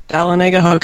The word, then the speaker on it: Dahlonega: Dahlonega. Okay, I got that right.